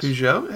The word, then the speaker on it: Peugeot: He drives a, I can't even pronounce it, it's French, Peugeot. – Yes. – Peugeot.